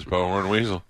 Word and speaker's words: Bowhorn Weasel: B-O-W. Second name, Horn. H-O-R-N. – Oh, there it is. Bowhorn Weasel.